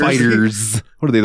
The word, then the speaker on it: FighterZ: Is it just Fighters? – It is just Fighters. – I always thought it was FighterZ too.